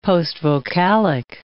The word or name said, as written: postvocalic